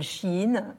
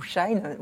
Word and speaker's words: Shein: Mais vous avez aussi une grande partie des Français qui adorent, vous savez, ce nouveau site euh, euh, de produits chinois qui s'appelle Shein ou Shine, on peut le prononcer des deux manières, et qui cartonne.